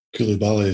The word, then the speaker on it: Coulibaly: There's that other guy, Lassana Kubali. Am I pronouncing that right? – Coulibaly is Coulibaly.